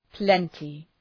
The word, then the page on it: plenty: ‘plentı